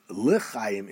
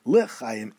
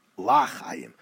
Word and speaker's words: l'chaim: Mishnah Berurah continues and tells us the word lechayim is the way to pronounce it, yomar b'shva, with a eh sound, the shva sound, lechayim, and not tachas alamit, and not lo yomar b'patach, lachayim, the lo yishtama lachayim, because that can be mistaken, kolomar lo chayim. So therefore we say lechayim and not lachayim, which could be mistaken for lo chayim, rachmona litzlan.